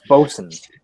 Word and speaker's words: bosun: It's pronounced bosun. – Bosun? – Bosun, like bosun. – Yeah, there's an alternative spelling, it's B-O-S-U-N.